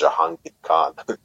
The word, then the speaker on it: Jahangir Khan: It was Jahangir Khan.